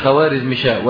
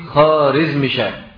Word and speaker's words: خوارزمشاه: وعلى فكرة النطق الصحيح مش خوارزمشاه ولكنه ايه؟ خارزمشاه. الواو دي بيسموها الواو المعدولة في اللغة الفارسية، يعني في اللغة الفارسية بيسموها الواو المعدولة، لا تنطق، لا تنطق، ولكن احنا بنقولها لان الناس تعودوا على ذلك، ولكن صح نقول ايه؟ خارزمشاه مش خوارزمشاه.